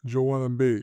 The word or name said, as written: Joel Embiid